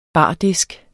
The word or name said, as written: bardisk